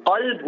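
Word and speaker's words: qalb (قلب): If I'm saying qalb with a qaf, it's heart. If I'm saying kalb, it means dog.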